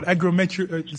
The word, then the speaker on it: agrometeorology: We're trying to figure out agrometeorology.